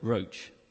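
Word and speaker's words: ruach (רוּחַ): The Hebrew word there that we translate as spirit all the way through the Bible is a word called ruach. R-A-U-C-H. That's how I pronounce it. My Hebrew isn't great, but ruach. And that actually means in Hebrew breath or wind.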